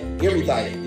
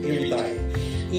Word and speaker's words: eritheia: And it's this Greek word, erithia, yeah, that's it, that's how you pronounce it, erithia, E-R-I-T-H-E-I-A, erithia.